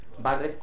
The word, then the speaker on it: Barrett: Él dice, en, hay una carta donde dice Barret con doble T, porque a veces también recuerdo con una sola T, con doble T y con acento en la A. – Lo dice. – Sí. Se pronuncia Barre.